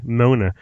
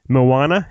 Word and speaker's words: Moana: I was gonna say Mona. Moana.